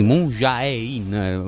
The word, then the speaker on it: Moon Jae-in: Si pronuncia Moon Jae-in secondo te? Sì, Moon Jae-in, ma vabbè, insomma.